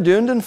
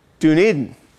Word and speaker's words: Dunedin: Dan from another Dunedin, Florida. It's pronounced, Dunedin. Dunedin.